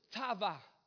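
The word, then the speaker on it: tava (תבה): The Hebrew word is tava. Say it together, tava.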